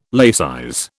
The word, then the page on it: laicize: British English